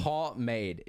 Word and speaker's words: pomade: It's pomade. It's not that hard to say. Pomade. Pretty much. That's all you have to say. Pomade. Yep. – But that's not the way it looks like it's spelled, though. – Welcome to English. It's not an easy language.